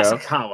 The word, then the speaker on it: Nasukawa: Nasukawa, I think, is how it's pronounced.